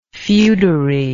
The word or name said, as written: feodary